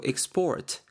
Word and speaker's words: export: export x가 아니고 export 강세도 뒤에 들어가죠. export 이렇게 발음이 되고 하면은 몸액상 동사로 쓰인 게 되겠습니다.